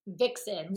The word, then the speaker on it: Vicsen: Vixen, like Vixen. And like Vixen being sexy, like okay. V-I-X-E-N. So this is Vic, V-I-C-S-E-N. Vixen. – Okay, you literally have a graphic explaining this, and I was still struggling. So thank you. – Yeah, no, it's Vixen, like how we've always heard, like what a Vixen is.